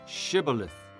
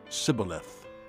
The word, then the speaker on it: Shibboleth: If he replied, No, they said, All right, say Shibboleth. – If he said, Sibboleth, because he could not pronounce the word correctly, they seized him and killed him at the fords of the Jordan.